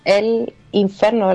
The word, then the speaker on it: L'Inferno: Bueno, el Inferno, la verdad, es la pronunciación, pero uh-huh.